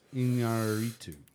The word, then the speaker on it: Iñárritu: Alejandro Gonzalez Iñárritu.